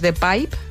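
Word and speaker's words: De Pijp: A mí un barrio que me gusta mucho también es The Pipe, que se escribe P-I-J-P.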